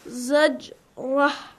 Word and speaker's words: زجرة: So if you make a pause, if you make a pause at zajr, how do you say that? – Zajrah.